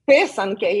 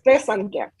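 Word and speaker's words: pisanki: Is it called Pesanki? – Pesanke, yes. You said it right, just the enunciation on the first part. Pesanke.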